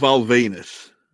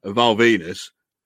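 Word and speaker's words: Venis: Val Venus, who, you know, is the right-wing moral majority now, but still goes by the name Val Venus, which rhymes with penis, even though, you know, is, you know, some right-wing nut job.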